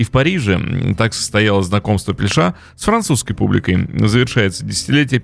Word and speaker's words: Пельш: В 96 году э, Пельша, ну так, наверное, его правильно э, именовать, с ударением на последний э, слог, дебютирует в мюзик мюзикле, сыграв роль Пикассо э, в «Жизнь в голубом» Роберта Осейна.